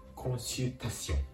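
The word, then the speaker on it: consultation: コンシルと発音するところがポイント。ですので、続けると、コンシルタシオン、コンシルタシンとなります。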